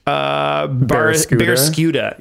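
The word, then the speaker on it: Barraskewda: I don't remember how to pronounce this fishy boy's name. What is it? – This was Barrascuda.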